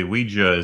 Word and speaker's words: Ouija: Actually, Ouija is the preferred pronunciation.